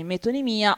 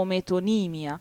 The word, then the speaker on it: metonimia: Allora, dicono però, dalla Treccani, per prendere il primo risultato, che si dice in entrambe, la parola si pronuncia con entrambe le accentazioni, metonimia o metonimia.